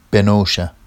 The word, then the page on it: benowʃa: Vafsi